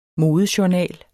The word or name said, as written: modejournal